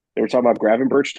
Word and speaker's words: Gravenberch: Amrabat and Gravenberch.